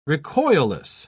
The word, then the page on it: recoilless: British English